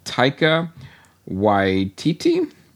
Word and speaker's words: Taika Waititi: It's I'm going to butcher his, the director's name, Taika Waititi.